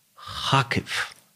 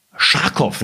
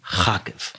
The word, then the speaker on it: Kharkiv: Und damit haben wir schon den Einstieg, denn Elena kommt gebürtig aus Kharkiv. Sehr gut. Ich habe es richtig ausgesprochen. Das, was wir immer gemeinhin Scharkow nennen, ist eigentlich Kharkiv.